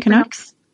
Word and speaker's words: Canucks: Canucks? Canucks? Mm-hmm. How do you Canucks. – Pronounce? – Canucks. – Canucks. Okay.